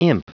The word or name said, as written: imp